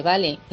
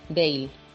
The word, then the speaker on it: Bale: Es como si a Bale, por el hecho de que ahora mismo esté en España, empezamos a llamarle Vale. No, su nombre es en inglés, Bale.